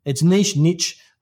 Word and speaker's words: niche: But it's English, British English. So it's niche.